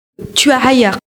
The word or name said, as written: Hayar